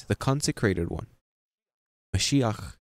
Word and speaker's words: Mashiach: Yeshua, Mashiach, would be in the Hebrew pronunciation.